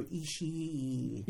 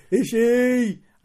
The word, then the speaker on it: Ishii: So Dave Mastiff had a match against Tomohiro Ishii today. I kind of resent people who insist on pronouncing the second E sound.